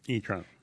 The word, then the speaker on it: e-tron: E-tron. – E-T-R-O-N.